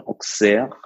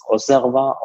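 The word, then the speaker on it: Auxerrois: Die Stadt wird ja mit X ausgesprochen, Auxerre. Der Wein, meines Wissens nach, wird er als Osserwa ausgesprochen, also X wie S. Man kann ihn aber auch Auxerre aussprechen.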